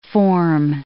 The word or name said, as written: forme